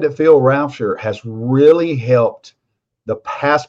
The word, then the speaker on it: Rauscher: How you pronounce his last name? – Rauscher, yeah.